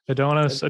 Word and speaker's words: Adonis: adonis adonis, adonis is fine yeah you're going the british way i am going, going the british.